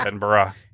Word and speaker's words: Edinburgh: Edinburgh, Scotland.